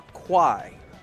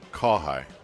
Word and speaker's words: Kawhi: Happy birthday from Kawhi. His leader is the snake. – All right, what's up, Kawhi? – And would like Kawhi. To hear a jub laugh. – Is it Kawhi or Kwai? – Kawhi. – Spell it like Kawhi or K-W-A-I or K-A-I.